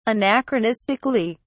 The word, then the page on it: anachronistically: British English